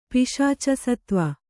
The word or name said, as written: ಪಿಶಾಚಸತ್ವ